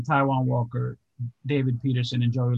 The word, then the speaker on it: Taijuan: I think having Carrasco on his team, you're able to bump a guy like Taijuan Walker because you guys are butchering his name. So it's Taijuan Walker.